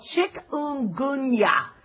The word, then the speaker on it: chikungunya: Dengue, fever, chikungunya. I can't pronounce that right. It's called chikungunya.